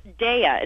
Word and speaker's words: Daiya: There's Daya. It's spelled D-A-Y. I'm sorry, D-A-I-Y-A.